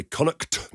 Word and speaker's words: Connacht: Got to say it that way, Connacht. – Nicely pronounced.